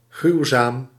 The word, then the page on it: ужасный: [uˈʐasnɨj]